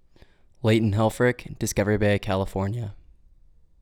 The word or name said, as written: Leighton Helfrick